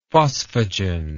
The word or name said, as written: phosphagen